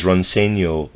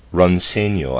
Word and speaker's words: Roncegno: Footnote, Ronsenio, I'm pronouncing that Ronsenio, and it's spelled R-O-N-C-E-G-N-O, and Levico, L-E-V-I-C-O, are mineral waters from the Dolomites, limestone mountains in northern Italy.